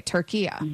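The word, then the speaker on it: Türkiye: Okay, Turkiya. – There you go. That's perfect. That's how it's pronounced. It's always been pronounced that in Arabic language.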